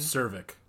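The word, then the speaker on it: Czervik: How do they pronounce it in the movie? – Cervic. – Cervic. Yeah, that makes sense. There's a lot of sexual innuendos in this. Sounds like cervix.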